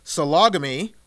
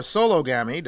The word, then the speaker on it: sologamy: Messi is part of a self-marrying movement dubbed sologamy, or sologamy, depending how you pronounce it, that has followers all over the world.